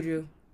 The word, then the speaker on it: Buju: It has Buju. Is that how you say it? Buju? – Yeah. – Buju Banton? – Buju.